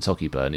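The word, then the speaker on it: Sauchieburn: Socky, as in Socky Burn, is pronounced Socky, and Lochmaben is pronounced Lochmaben.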